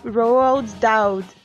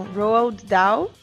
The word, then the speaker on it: Roald Dahl: baseadas nos livros do Rob Roald Dahl. Não, Roald Dahl. Tá vendo? Nem sempre eu sei pronunciar as coisas em inglês.